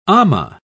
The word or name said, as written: amah